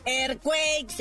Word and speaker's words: Earthquakes: Aprenda, mi Chofis. Earthquakes, Earthquakes se pronuncia.